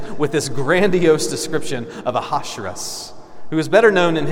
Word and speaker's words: Ahasuerus: that's what I'm going to call him because it's a lot easier to say than Ahasuerus, which is the correct pronunciation.